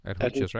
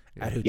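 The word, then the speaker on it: Adjuchas: is that how I think that's pronounced correctly? – Ajukas, right? – Ajukas. Yes.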